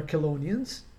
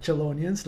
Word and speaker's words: Chelonians: which are Chelonians, not Chelonians, like Mark pronounced them.